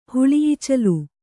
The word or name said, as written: ಹುಳಿಈಚಲು